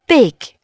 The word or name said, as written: big